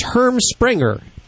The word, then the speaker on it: Herm Sprenger: It's Herm. I always mispronounce. Hell, could you pronounce it right? – You actually do a good job. It's Herm Springer. – Yes, Herm Springer pinch collar. – And it's spelled H-E-R-M-S-P-R-E-N-G-E-R.